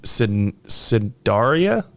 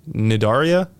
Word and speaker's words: Cnidaria: It's called, and I'm going to butcher this pronunciation, it's Cidaria? Nidaria? It's C-N-I-D-A-R-I-A.